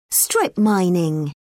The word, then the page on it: strip mining: British English